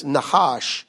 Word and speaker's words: Nahash: How do we pronounce it in the South? Nahash. Is that the way? Yeah. In Hebrew, it's Nahash.